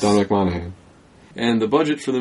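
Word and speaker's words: Monaghan: It stars Hugh Jackman, Liev Schreiber, Danny Houston, Will.i.am, Lynn Collins, Kevin Durand, Dominic Mahogany, Monahan, in my head, silent G, and I just said the G anyway.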